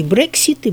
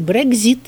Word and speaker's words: Брексит: Потому что писали о том, что этот Брексит, кстати, его произносят по-русски Брексит и Брекзит, я уже там за этим внимательно посмотрела, выходить начинают и другие.